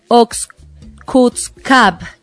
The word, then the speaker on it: Oxcutzcab: Oxcutzcab, Yucatán.